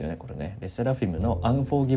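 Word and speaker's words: LE SSERAFIM: でかつあのこれ k p o p ファンの方チェックしたかわからないですけどレセラフィルムこれ発音合ってますレセラフィムレセラフィルムじゃないね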